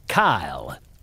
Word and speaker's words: Kayl: The surly one is Kyle, spelt K-A-Y-L. He gets upset if you misspell it.